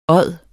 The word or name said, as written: odde